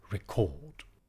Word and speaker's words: record: Here, for prosody, the word is stressed on the second syllable, record.